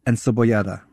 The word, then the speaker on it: encebollada: Encebollada.